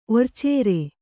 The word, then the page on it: ಒರ್ಚೇರೆ: Kannada